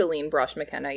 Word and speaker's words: Aileen Brosh McKenna: I'm not sure how you pronounce her name, the writer, Aileen Brosh McKenna? – Yeah, Aileen? I think Aileen Brosh McKenna, yeah.